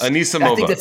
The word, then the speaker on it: Anisimova: Am I saying that right, by the way? Anamasova? – No, I think you're saying it wrong. – Anisimova. – Anisimova. – You're switching the S and the M.